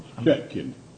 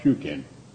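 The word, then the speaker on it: Chutkan: Your colleague has the pronunciation of the district judge correct. Chetkin is the district judge, not, as your colleague stated, the district judge is Chetkin, not Shukin, or okay.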